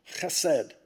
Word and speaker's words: chesed: and to say it right, you have to go chesed, and often translated loving kindness, but just tremendous, you know, it's what motivated Paul.